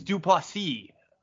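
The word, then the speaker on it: du Plessis: Except for Dracus Duplessis, who, by the way, I'm pretty sure we continue to say his name wrong. It's Duplessis.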